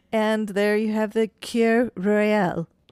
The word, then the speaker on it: Kir: K-I-R. Cur.